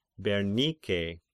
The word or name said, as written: Bernike